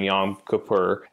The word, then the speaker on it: Yom Kippur: But we saw it in his, so he misses the first preseason game because he was, observing Yom Kippur, and then in his second one. – That was a good pronunciation there.